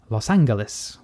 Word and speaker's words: Los Angeles: Or as they used to say at the time, Los Angeles, with the hard G.